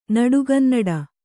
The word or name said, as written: ನಡುಗನ್ನಡ